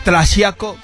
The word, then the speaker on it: Tlaxiaco: Tlaxiaco.